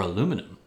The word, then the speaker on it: aluminum: Nine is pottery. 10 is aluminium. – Or aluminum, as we say.